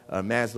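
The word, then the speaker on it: Hermès: Hermes looks a certain way, and now we buy his bags, H-E-R-M-E-S, that's Hermes. I know a lot of people pronounce it as Hermes. It's not.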